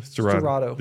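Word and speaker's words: Sterrato: And these guys, how do you pronounce it again? – Storato.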